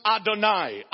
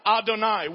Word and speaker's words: Adonai: It is synonymous with the Hebrew word for God as Lord that is pronounced Adonai, Adonai, which means the sovereign one, the absolute ruler, the one who decides and determines and dictates without consulting anyone else.